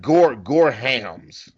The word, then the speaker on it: Gorham: Like, I'm out of Gorum meds. – Is it G-O-R-U-M? – Yeah. – No, it's like Gorhams. – Oh, Gorham.